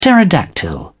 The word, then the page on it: pterodactyl: British English